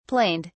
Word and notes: planed: British English

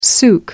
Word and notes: souk: American English

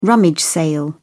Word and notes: rummage sale: British English

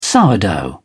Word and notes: sourdough: British English

